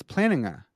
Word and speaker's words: Plantinga: Never ever say Plantinga. That is the worst pronunciation. – It's pretty bad. I think Tyler McNabb says it weird though. I think he says Plantinga. Like he's such a Plantinga fanboy. He can't say it wrong. – It's I think you just said it weird too. It's Plantinga, right? It's Plantinga. Yeah, yeah, yeah.